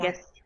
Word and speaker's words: Animagus: Also, being an Agmanus, I didn't know I said that wrong. A person who's able to transform. – Animagus.